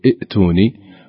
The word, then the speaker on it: ائتوني: صحيح، نعم فعلا، إذا بدأت الكلمة بهمزة وصل وكان بعدها همزة ساكنة من أصل الكلمة مثل ائتوني أو ائتيا أو ائذلي، لا ننطقها بهجائها هكذا، لا نقول ائتوني ولا نقول ائذلي، نقول ائتوني ائذلي.